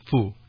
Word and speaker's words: Fu: I'm going to guess you gave me an anglicized version, an easy-to-pronounce version of your name, no? – Yes, Moses Fu, F-U.